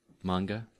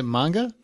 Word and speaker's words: manga: Manga. – Is it manga? – Mm-hmm. – This show's only got. – I've always pronounced it manga, but I'm just a stupid American.